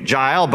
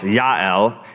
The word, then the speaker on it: Yael: Okay, Jael, by the way, there's no J in the Hebrew alphabet. Her name really is spelled with a Y. It's Yael, and her name means, Yael in Hebrew means wild mountain goat.